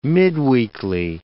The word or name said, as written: midweekly